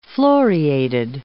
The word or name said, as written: floriated